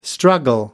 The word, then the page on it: struggle: American English